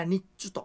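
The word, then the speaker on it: 根人: はいで末期の始めに変わる古い家なんですねでその新谷の男の人でトップねあの家人のトップをネビトと書いて日中これネビトと読まない日中あ日中と言って読んだりしま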